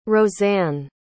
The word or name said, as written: roseanne